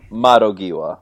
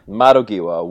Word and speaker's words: Madogiwa: He's joined the Mondagiwa? I'm saying that wrong. – It's something like that. – Mondaganuki? Monda... I'm gonna. – Is it like Mondai Genoa or something like that? – Madogiwa. Madogiwa. Windows.